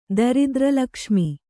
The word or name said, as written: ದರಿದ್ರಲಕ್ಷ್ಮಿ